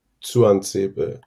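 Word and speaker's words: Tuanzebe: Juan Sebe, wird das so ausgesprochen? – Genau. Ich habe mir ein Twitter-Video von Aston Villa mal angeguckt. Das T vorne spricht der selber wie so ein Juan Sebe.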